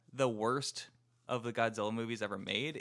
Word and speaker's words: Gojira: Godzilla. Godzilla. – It's pronounced Gojira.